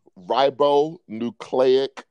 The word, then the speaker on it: ribonucleic: What's the pronunciation again? Say that again. – Ribonucleic acid.